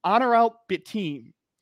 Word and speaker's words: Onuralp Bitim: Onuralp Bitim was a two-way player from Turkey.